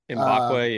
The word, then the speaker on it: Mbakwe: Jalen Mbakwe? – Mbakwe, yeah.